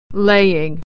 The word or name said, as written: laying